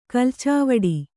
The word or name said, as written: ಕಲ್ಚಾವಡಿ